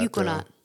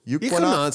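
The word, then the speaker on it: Eukanot: How do you say that? – Euquanot. – Euquanot sounds good to me.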